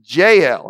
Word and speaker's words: Jael: Which kind of reminds me of when we lived in southwest Georgia and everybody pronounced Gail, Gail. Okay, but this is Jael.